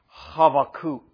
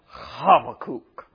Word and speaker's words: Habakkuk: We'd pronounce the prophet's name Habakkuk. Habakkuk. Okay? And if you want to really pronounce it well in Hebrew, you've got to give that first letter a H kind of sound. Habakkuk.